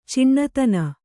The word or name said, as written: ಚಿಣ್ಣತನ